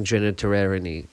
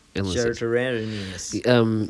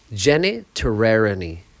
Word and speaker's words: genitourinary: And it just reduces their risk of contracting genitourinary problems. – Genitourinous.